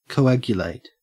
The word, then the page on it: coagulate: Australian English